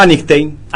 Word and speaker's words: Einstein: Einstein.